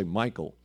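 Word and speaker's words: Michal: So David says to Michael, I call her Micah, but actually the way it's pronounced, I should just say Michael.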